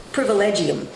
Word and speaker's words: privilegium: How do you pronounce that? Privilegium? – Privilegium.